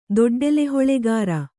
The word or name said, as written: ದೊಡ್ಡೆಲೆಹೊಳೆಗಾರ